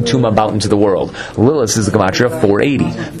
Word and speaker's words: Lilith: Lilith, I'm not saying it with the th, because that's how it's supposed to be pronounced.